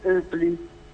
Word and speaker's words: Hoepli: Oh, vi do una dritta, se volete anche andare su online per farvene un'idea, ci sono questi scaffali Oepli. Oepli si scrive H-O-E-P-L-I, Oepli con la H, mi raccomando.